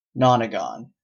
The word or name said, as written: nonagon